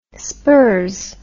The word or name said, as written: spurs